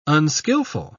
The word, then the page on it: unskillful: British English